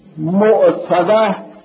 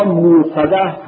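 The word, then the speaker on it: مؤصدة: اِنَّهَا عَلَيْهُمْ مُؤْصَدَه یا مُؤْصَدَه هر دو درسته، یعنی مُؤْصَدَه اصل مُؤْصَدَه و بعد به اصطلاح علمای سر تخکیف همزه می شود و مُؤْصَدَه تلفظ می شود.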